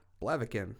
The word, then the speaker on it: Blaviken: The butcher of Blavikav. Blavikov? – Blaviken. – Blaviken.